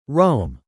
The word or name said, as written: roam